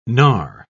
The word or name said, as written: gnar